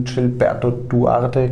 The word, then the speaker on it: Gilberto Duarte: zwei Leute dazu bekommen mit dem Gilberto Duarte, glaube ich, genau. genau richtig ausgesprochen.